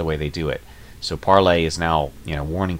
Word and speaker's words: Parler: And now they are warning, Andrew Torba is warning, that Parlay, or Parler as it's spelled, has bent the knee, as he's saying, to big tech. Parlay is now, it's spelled Parler, it's pronounced Parlay,